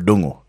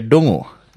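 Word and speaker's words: Ndungu: So lakini I'm here in a foreign land, watu wajui kuspell jina yako, wajui kupronounce, so nikaenda nikasema naitwa Paul Dongo. Hey, wakashangaa hii jina ni gani? – Shit. – Nikasema okay. pull up. Can you say that slower? Nikamwambia Dongo.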